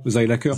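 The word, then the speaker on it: Seilacher: Sélacher, j'imagine que je le prononce bien. – Oui, ça c'est la prononciation française, Zeilacher, ouais. En allemand, Zeilacher.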